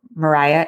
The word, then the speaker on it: Maria: Mariah is spelled Maria, as well as his preteen cousin, Virginia Clem.